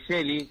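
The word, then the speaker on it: Geely: Porque es el desembarco de la primera marca china, Yeli. ¿Así se pronuncia? – Sí, efectivamente, es Yeli, como se pronuncia.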